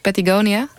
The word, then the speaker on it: Patagonia: Je hebt de kunstenaar Patagonia getipt. Zeg ik dat goed? Patagonia? – Ja.